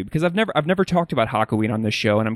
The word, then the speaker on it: Hakuin: Hakuin.